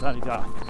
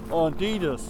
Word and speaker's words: Adidas: Which I think is actually the proper pronunciation in French, is Adidas. But leave it to an American to go, Adidas.